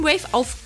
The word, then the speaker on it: Wayf: Die werden nämlich W-A-Y-F geschrieben, aber trotzdem Wave ausgesprochen.